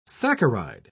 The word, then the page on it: saccharide: British English